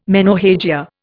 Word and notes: menorrhagia: Indian

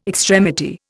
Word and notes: extremity: Indian

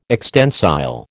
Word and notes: extensile: American English

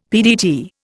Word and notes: PDT: Indian

